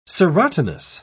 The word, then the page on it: serotinous: British English